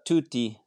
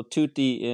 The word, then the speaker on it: Tutti: Am I pronouncing it correctly? – Yeah, it's pronounced Tutti. So Tutti in Italian means everyone or all.